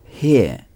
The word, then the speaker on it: here: Ça s'écrit H-E-R-E. Here. Here. Le H en anglais est toujours un H aspiré, prononcé H. Here. – Here. – Très bien. Here. Here. Il est toujours très important de prononcer le H.